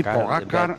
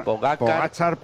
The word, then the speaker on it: Pogačar: Sí, Tadej Pogačar, Pogačar.